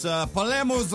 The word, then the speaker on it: Palermo's: It's Palermo's Ristorante Italiano, and that's how you pronounce it.